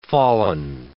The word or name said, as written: fallen